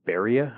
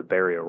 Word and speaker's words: Berea: These samples that they collected on March the 30th, these were taken from a rock that has been named Beria. I believe that's how you say it, B-E-R-E-A.